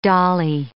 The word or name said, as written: dolly